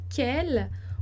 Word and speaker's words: quelle: Então, o quelle, o nosso pronome interrogativo, que é qual, vai concordar com couleur. Tá? Vai ser feminino também e no singular. E a pronúncia, como eu falei, vai ser a mesma.